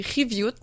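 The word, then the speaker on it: qiviut: Et il y a le kiviut, qui est prononcé riviut en inuktitut.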